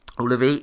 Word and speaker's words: Ullevi: They played Ullevi. Am I saying that right? The stadium? – Ullevi. – Ullevi, yeah, which is a really cool stadium, actually.